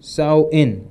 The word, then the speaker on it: Samhain: So there's a name, it's written Samhain, but it is actually pronounced Samhain, okay?